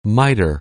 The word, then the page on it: miter: anglais